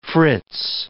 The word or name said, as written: Fritz